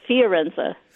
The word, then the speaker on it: Fiorenza: Can I just say I read about this and you actually talked about a class for feminists about Forenza. Is it Forenza? How do you pronounce her last name? Elizabeth? – Oh, Elizabeth Schussler Fiorenza.